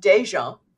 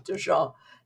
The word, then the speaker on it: DeJean: I'm my name is pronounced Dijon in Louisiana. Yeah, but I have given up trying to explain this variant long ago, so I just go with Dijon except when I'm in Louisiana. I tell people because we're a clan, a numerous clan down there.